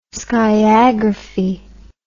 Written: skiagraphy

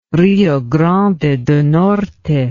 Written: Rio Grande do Norte

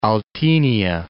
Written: Oltenia

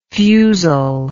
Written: fusilly

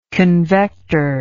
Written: convector

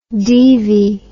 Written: deevy

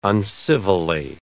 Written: uncivilly

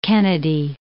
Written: Kennedy, Mount

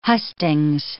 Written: hustings

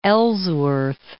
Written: Ellsworth

